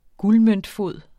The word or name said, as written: guldmøntfod